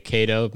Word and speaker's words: Kaito: Let me spell it for you. K-A-I-T-O. That's the first name. – K-A-I-T-O. Yeah. Okay, Kato makes sense. – O-N-I-S-H-I. – O-N-I-S-H-I.